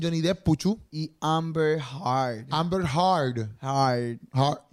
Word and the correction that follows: Heard: Heard. Heard. No sé cómo se dice la apellido. Es e H-E-A-R-D. Heard. Heard, Heard. Amber Heard.